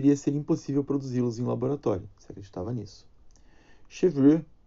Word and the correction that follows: Chevreul: Chevreux, é, Chevreul, que se escreve, explicou a natureza das gorduras.